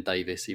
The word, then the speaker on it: Davies: Same, I'm gonna have to say it's Ben Davis, even though I've been calling him Ben Davies all my life. gonna get the pronunciations right this season.